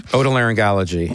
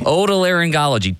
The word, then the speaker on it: otolaryngology: Otolaryngology. – Otolaryngology.